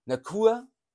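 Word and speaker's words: Nacua: Out ist für dieses Wochenende Bennett, der Quarterback, dann Naker und Duran. Diese drei, also Duran und Naker, ja, wie wird der ausgesprochen? Nacur, der Wide Receiver, Puka Nacur und Duran sind noch auf Questionable.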